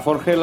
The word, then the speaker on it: Forges: Es Forge, ¿no? Se pronuncia. Forges.